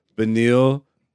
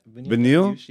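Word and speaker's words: Beneil: Charles Oliveira versus Benil Dariush. Did I say his name right? – Yep. – Benil? – Yep.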